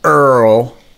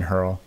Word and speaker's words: hurl: Um, when there's too much hatred in the world, when the public makes you want to earl, he says Earl. He doesn't pronounce the H in hurl.